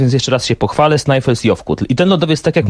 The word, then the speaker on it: Snæfellsjökull: Snæfellsjökull.